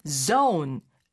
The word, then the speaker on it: zone: 어떤 영역이란 뜻으로 쓰게 되긴 하는데, 그러나 zone 이렇게 발음해야 상대가 알아들을 수 있다고 합니다.